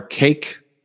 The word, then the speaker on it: CAIQ: And last, my favorite acronym so far, CAIQ, however, it is spelled C-A-I-Q, which stands for the Consensus Assessment Initiative Questionnaire.